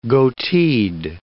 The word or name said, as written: goateed